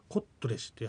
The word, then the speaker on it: コットレシ: これもコロトレシなんですが、発音するときには、コットレシという発音になります。